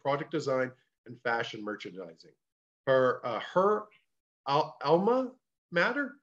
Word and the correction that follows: alma mater: Is that how you pronounce that? – Yeah, alma mater.